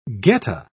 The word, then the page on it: geta: British English